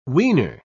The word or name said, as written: wiener